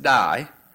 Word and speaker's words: dei: It's a little word spelled D-E-I, and I believe it's pronounced die.